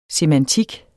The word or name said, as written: semantik